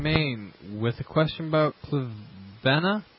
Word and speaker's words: clivia: Hey, Paul, I have three clivias. I think that's how you pronounce it. – Yep.